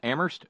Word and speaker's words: Amherst: Thank you very kindly to Terry in Amherst, where they don't pronounce the H.